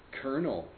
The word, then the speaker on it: colonel: How do you pronounce this word? Kernel, yes.